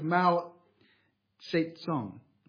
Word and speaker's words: Mao Zedong: We might think of Mao Zedong.